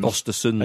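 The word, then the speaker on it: Östersund: Frank de Boer, Yapstam, Chris Coleman, Swedish side Osterund's manager... Graham Potter. – Ostersons. And you're a host of On the Continent, so you knew the correct way to pronounce that.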